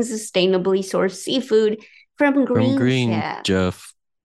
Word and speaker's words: Chef: I said chef. Chef. Green Chef. Uh-huh. Okay. – All right. – Yeah, I wasn't sure why it was sounding weird, but I forgot you pronounce it like SH.